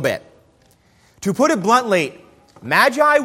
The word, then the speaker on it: magi: Now, the Greek word that is translated here as wise men, in Greek it's magi, or as it is commonly pronounced in English, magi.